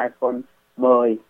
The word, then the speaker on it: iPhone X: nhưng mà không phải đọc là X mà là iPhone 10 La Mã.